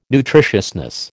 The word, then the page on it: nutritiousness: American English